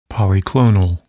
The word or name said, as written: polyclonal